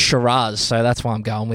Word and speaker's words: Shiraz: Now, you know why I'm going with Shiraz? – Why? – Because that's how it's supposed to be pronounced.